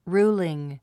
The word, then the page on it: ruling: rúːliŋ; ルーリン